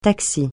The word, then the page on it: taxi: francais